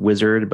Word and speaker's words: Uizard: And you can look right now at tools like, I think it's pronounced Wizard, but spelled U-I-Z-A-R-D, where you can sort of provide a text prompt and it will create an interface for you.